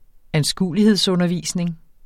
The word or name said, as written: anskuelighedsundervisning